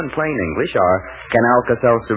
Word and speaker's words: Alka-Seltzer: Just remember that A-L-K-A-S-E-L-T-Z-E-R spells relief, although it's pronounced Alka-Seltzer.